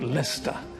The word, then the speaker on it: Leicester: The letters L-E-I-C-E-S-T-E-R are not pronounced Leicester, but Lester. The letters O-U-G-H are pronounced in at least four different ways: cough, bow, though, thought.